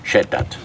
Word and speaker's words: Schettat: Schreibt man dann S-C-H-E-T-T-A-T, Schettert.